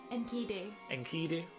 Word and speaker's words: Enkidu: Enkidu.